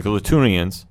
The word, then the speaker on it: Klatoonians: Yeah, so the Klatoonians, did I say that right? – Yes.